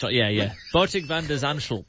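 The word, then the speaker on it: Botic van de Zandschulp: Did I say that right? Botic van der Zandschlup? – Yeah, the first. Botic van der Zandschlup.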